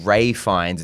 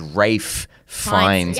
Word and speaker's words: Ralph Fiennes: So it's not Ray Fiennes, it's Rafe Fiennes, but they say it like it connects.